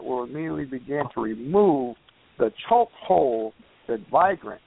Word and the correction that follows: vagrant: as I'm pronouncing that right, Brother Pat, vigrants have on our city. – It's vagrant. Vagrant or vagrancy.